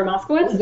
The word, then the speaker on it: Moskowitz: Issa, how do you say her last name? Issa Chandra Moskowitz? – Yes.